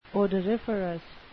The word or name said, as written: odoriferous